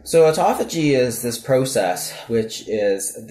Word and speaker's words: autophagy: I've heard it pronounced two ways, autophagy or autophagy. Okay. How do you pronounce it? – I say autophagy.